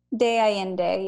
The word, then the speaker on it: San Miguel de Allende: So that is how you ended up in, I always pronounce it wrong. San Miguel de Allende? – De Allende, yeah. – De Allende, okay.